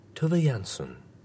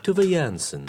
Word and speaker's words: Tove Jansson: Tove Jansson. Oh, it was so much. I kept pressing that button over and over again. Tove Jansson. – Tove Jansson. – Tove Jansson. I always thought it was Tove Jansson. T-O-V-E.